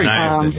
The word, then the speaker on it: niacin: Sure, niacin.